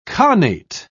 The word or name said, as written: khanate